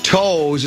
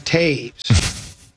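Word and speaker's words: Toews: This one says, Common, it's not toes, it's pronounced Taves.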